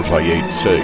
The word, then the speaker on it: Vayetze: I'm your host, Phil Chernofsky, and this show is for Parshat Vayetze, or Vayetze, as would properly be pronounced.